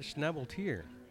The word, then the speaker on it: Schnabeltier: But we're at Schnabeltier, which did you pronounce it right? – Yeah, you did. I was going to say. – . Every once in a while, somebody nails it.